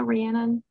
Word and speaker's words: Rhiannon: Rih- Rihanna. I don't know if I'm pronouncing it correctly. It's um, Rhiannon, um, like the um, Fleetwood Mac song.